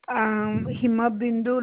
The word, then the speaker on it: Hima Bindu: Hima Bindu Labu, I spell it.